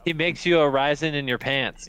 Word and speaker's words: Arizin: You got Paul Arizin. I don't know if I'm saying that right. – Paul Arizin. – Arizin. Rick Barry. I think it's Arizin.